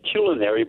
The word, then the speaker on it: culinary: So I think we should be saying culinary, but nobody does. – Culinary? – Culinary. – Culinary. – C-U, then the next syllable. – Yeah. – Culinary. – Culinary. – As opposed to culinary. – Culinary. – It's not C-U-L, it's C-U hyphen, if you look in the dictionary. And it's not C-U-L, it's not culinary.